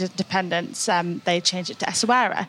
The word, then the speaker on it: Essaouira: So Essaouira, finally, we've got the pronunciation right.